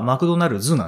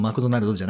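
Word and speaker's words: McDonald's: はい、だから、本当はマクドナルズなんですよね。 – ああ。 – もちろん英語の発音はマクドナルズってなりますけど、発音マクドナルドじゃないんですけども、はい。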